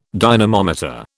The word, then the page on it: dynamometer: British English